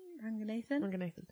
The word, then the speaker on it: Ranganathan: Ramesh Ranganathan, is that how you say his name? – Ranganathan. Yeah.